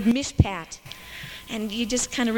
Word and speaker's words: mishpat: Justice, mishpat. Yeah, in English we'd spell it M-I-S-H-P-A-T.